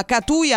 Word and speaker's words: Catui: questa manifestazione che si chiama Catui Aperti. L'ho detto bene, sindaco? – Sì.